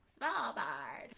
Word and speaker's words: Svalbard: Svalbard.